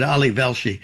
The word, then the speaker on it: Velshi: I think I mispronounced it, Ali Velshi.